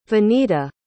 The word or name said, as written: vinita